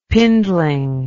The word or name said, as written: pindling